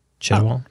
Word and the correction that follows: Chiwetel Ejiofor: Chiwetel Ejiofor.